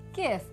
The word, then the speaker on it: GIF: G pronunciation, creamy peanut butter. GIF, animated looping images, hard G pronunciation.